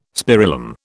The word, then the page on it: spirillum: British English